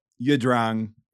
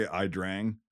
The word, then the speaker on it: Ia Drang: Well, Yidrang is like how it's pronounced, like whatever, but Americans say Ia Drang. So I'll just say Ia Drang from here on out.